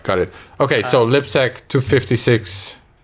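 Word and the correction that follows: libsecp256k1: am I saying that right? – libsecp 256k1.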